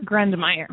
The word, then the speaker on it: Grundmeyer: Is it Gunmeyer? – Grundmeyer. – Grundmeyer. Okay. I missed a few letters in your last name there.